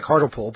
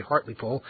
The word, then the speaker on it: Hartlepool: It looks like Hartlepool, but it's actually Hartlepool.